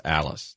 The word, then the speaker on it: Alles: The pronunciation gods say it's Alice, Tex Ailes.